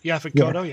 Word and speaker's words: Yaphet Kotto: So when Buzzsaw shows up on his motorcycle, Yaphet Kotto, did I say that right? – Yaphet Kotto, yeah.